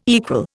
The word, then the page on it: equal: Indian